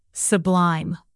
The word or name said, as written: sublime